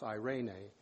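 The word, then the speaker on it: Irene (εἰρήνη): It's a Irene, and it's spelled E-I-R-E-N-E, but it's pronounced Irene.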